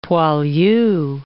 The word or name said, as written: poilu